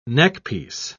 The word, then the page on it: neckpiece: British English